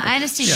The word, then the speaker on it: anesthesia: I would trust the experts when they thought it was safe because they've seen them come out of anesthesia. Am I saying it right? – Yeah, anesthesia.